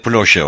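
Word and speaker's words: Ploče: Ploche. – Nerede? – Ploche. P-L-O-C-E yazılır ve Ploche okunur.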